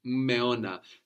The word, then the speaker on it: Meona: Meona. So you don't really pronounce the two M's separately, but you do pronounce them both. So the M becomes longer.